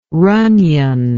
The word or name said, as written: ronion